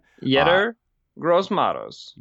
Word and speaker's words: Yetur Gross-Matos: Yetur Gross-Matos, I'm sure I butchered that name yet again. – Yetur Gross-Matos. – Yetur Gross-Matos. I was way off.